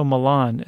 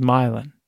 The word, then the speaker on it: Milan: You might know Milan as the city in Italy, but here locals pronounce that street as Milan.